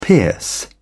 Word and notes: pierce: British English

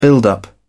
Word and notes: build up: British English

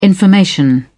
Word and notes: information: British English

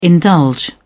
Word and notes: indulge: British English

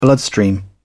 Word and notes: bloodstream: British English

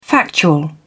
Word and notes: factual: British English

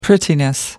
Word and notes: prettiness: British English

